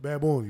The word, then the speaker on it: Bad Bunny: So Bad Bunny.